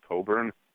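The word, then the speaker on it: Cockburn: The woman you were talking about earlier who's running for the Senate, her last name is pronounced Coburn.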